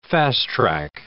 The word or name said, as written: fast-track